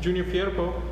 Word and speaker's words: Junior Firpo: Hey, they just signed a Spanish fullback, Junior Firpo.